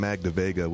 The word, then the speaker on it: Magda Vega: Robin came up with the name Magda Vega, which has been a blessing and a curse because no one can ever pronounce it right. It's M-A-G-D-A hyphen V-E-G-A.